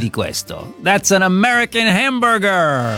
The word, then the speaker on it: hamburger: Sì, ma chi se ne frega, si pronuncia alla tedesca, hamburger.